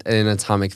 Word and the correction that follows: amniotic: fluid. Amniotic fluid. God, amniotic. Yes. Amniotic fluid.